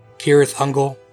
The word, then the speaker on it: Cirith Ungol: Also, in the book, unlike this movie, C sounds in Elvish are hard C. So Cirdan, Celeborn, Cirith Ungol.